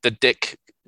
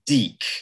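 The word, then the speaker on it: DiC: The Dick production company that produced them, that always used to make me laugh whenever I would see it. I think it's pronounced Deek.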